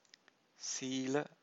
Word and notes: sila: Pali

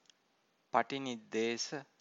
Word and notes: patiniddesa: Pali

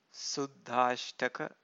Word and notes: suddhashtaka: Pali

